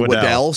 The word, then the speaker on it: Waddle: No one's going to call me Waddell, so I'll just live with it. But that is how it's pronounced.